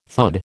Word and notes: thud: American English

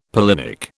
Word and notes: pollinic: British English

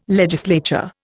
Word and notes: legislature: Indian

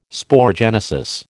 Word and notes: sporogenesis: American English

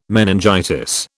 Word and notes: meningitis: British English

